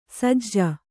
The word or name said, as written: ಸಜ್ಜು